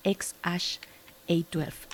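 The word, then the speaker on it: X Æ A-12: pues es así, ex ash. A12 es el nombre.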